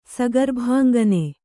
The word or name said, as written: ಸಗರ್ಭಾಂಗನೆ